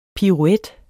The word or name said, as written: piruet